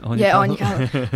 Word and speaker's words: Aine Cahill: Aine Cahill.